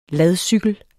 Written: ladcykel

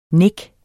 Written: nik

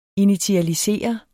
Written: initialisere

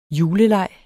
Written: juleleg

